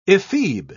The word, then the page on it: ephebe: British English